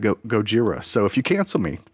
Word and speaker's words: Gojira: And I look it up and they're like, for the original Japanese pronunciation of Godzilla. And I laughed for a solid hour every time I would say Gojira.